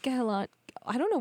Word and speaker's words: Kehlani: I think it's just Kehlani. – Kehlani. – Yeah. – I think I'm pronouncing the H, which is like a problem.